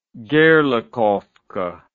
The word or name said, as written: gerlachovka